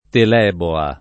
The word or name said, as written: teleboa